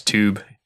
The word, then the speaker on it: tube: I also don't like how people from that side of the pond pronounce tube. It's like with a C-H. Tube. Tube. – Tube. – C-H-O-O-B.